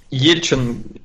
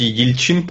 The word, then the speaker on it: Ельчин: А, слушай, но ну, Ельчин, или он, кстати, Ельчин, по-моему, во всяком случае, в Википедии его ударение так почему-то выставлено.